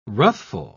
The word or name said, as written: ruthful